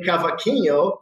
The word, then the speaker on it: cavaquinho: It's called a Brazilian cavaquinho.